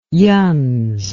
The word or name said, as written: youuns